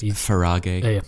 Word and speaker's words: Farage: It's pronounced Farage.